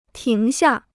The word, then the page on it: 停下: tíng xià